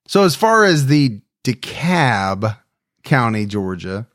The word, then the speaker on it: DeKalb: But I was calling to tell you that so the L in DeKalb is silent, just because I'm from Atlanta, and it can be very confusing. And I'm not getting on to you because a lot of people make that mistake. But if you ever have another DeKalb County situation, you can not have to try so hard to pronounce that L because it's a silent L.